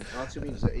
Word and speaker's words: Otium: Otium is the Latin pronunciation. So the TI is a Z, so Otium is the right Latin pronunciation, because it's a Latin word, as Barbara will explain.